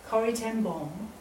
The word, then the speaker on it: Corrie ten Boom: But one of my heroes of the faith, Corrie ten Boom, as it's pronounced by the Dutch,